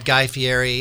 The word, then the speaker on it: Fieri: This is our first year that Guy Fieri is coming into our event. I'm pronouncing his name wrong, but I don't know how to do it. And that's pretty close.